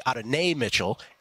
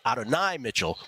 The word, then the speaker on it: Adonai Mitchell: Then people say Adonai Mitchell. And then, of course, those on the leaflet and those in the mishpucha, we say Adonai Mitchell, which I think is how you really should pronounce it. Just want to say it right now. Just want to be clear about that.